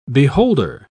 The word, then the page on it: beholder: British English